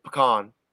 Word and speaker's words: pecan: Pumpkin pie or pecan pie? – Ooh, neither one are like that. Like, oh, I said that wrong. It's pecan. Sorry, I said it wrong.